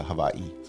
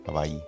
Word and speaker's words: Hawaii: Så Hawaii, som det för, för övrigt uttalas, det är ju, vi använder ju oftast amerikanernas sätt att uttala Hawaii. Men egentligen så är det ett enkel-V. W på Hawaii är egentligen ett enkel-V uttalat. Enkel-V uttalat. Och så ska det vara betoning på det sista i, så Hawaii.